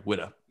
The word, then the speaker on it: Whydah: But it's pronounced the same either way, Whydah.